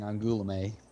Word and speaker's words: Angoulême: So this is actually the name of a town, city, I should say, in France, and we would pronounce it Angoulême. – Okay, good. I got it. I was right. – But that's a lot of nasal with the Angoulême. Yeah, and a real oo and not whatever like two o's would work.